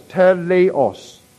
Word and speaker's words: teleios: It is the word T-E-L-E-I-O-S, pronounced as teleos.